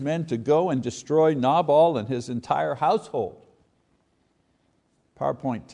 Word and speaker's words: Nabal: I mean, Nabal, and that's the way you pronounce his name, you know, Nabal.